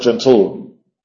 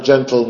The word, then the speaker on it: gentle: La única forma de pronunciar esta palabra no es gentil, no es gentil. Gentil es con una I en medio. La palabra gentle no tiene otra forma de pronunciarlo que gentle.